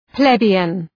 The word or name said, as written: plebeian